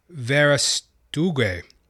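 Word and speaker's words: Verástegui: It is produced by Eduardo Verastue. Pardon my pronunciation there. Eduardo Verastugue, who also plays a role in the film.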